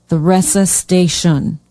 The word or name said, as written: theresa station